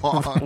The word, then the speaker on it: Wong: Is it Wang or Wong? – Wong. Wong. – Rhymes with dong. Wong, dong, Wong.